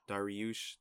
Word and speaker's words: Dariush: How you pronounce his name? Dariush? – Beneil Dariush.